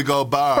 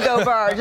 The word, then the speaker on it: Gobert: How do I say it, Perk? Rudy go who? – Rudy Gobert. – For Rudy Gobert.